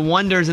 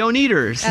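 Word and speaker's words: Oneders: The Wonders, and they first spelled Wonders O-N-E-ders, mm-hmm. like with the number one, and people were pronouncing it, oh, here they are, the O-N-E-ders.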